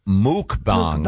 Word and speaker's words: mukbang: It's actually pronounced muk. Yeah. – Is it muk? – Mukbang. – Yep. Oh, I thought it was mukbang. – The pronouncer here is mook.